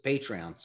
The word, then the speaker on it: Patreon: So why don't you talk about the Patron? Am I saying that right? Patron? – Patreon.